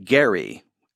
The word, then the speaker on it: Gerry: Gerrymandering takes its name from Elbridge Gerry with a hard G, although it's gerrymandering soft G.